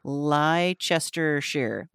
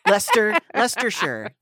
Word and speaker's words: Leicestershire: But this county in England, Leicestershire. – It's probably like, if you said it properly, it's seven syllables. But it's probably like Leicester. – Leicestershire. There we go.